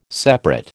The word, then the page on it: separate: American English